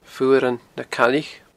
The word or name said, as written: Fuaran na Caillich